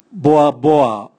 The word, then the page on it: boaboa: Raga